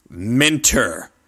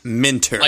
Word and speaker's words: Minter: I remember listening to an interview with Jeff Minter, or Mitner, however the fuck you pronounce his surname. – Oh, wait, I can do this one. Ready? It is Minter.